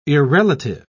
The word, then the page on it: irrelative: British English